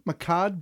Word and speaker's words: Mehcad: Makad, is that how you pronounce this? M-E-H-C-A-D, Makad Brooks, is going to be playing James Olsen on the CW's Supergirl. – Yeah.